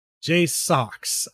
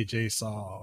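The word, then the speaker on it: JSAUX: I know that Jay Sox, I had to remember how to pronounce her name because they get really irritated when you say Jay Saw or Swee.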